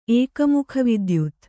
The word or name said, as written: ಏಕಮುಖವಿದ್ಯುತ್